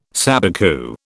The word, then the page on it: sabicu: British English